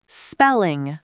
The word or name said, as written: spelling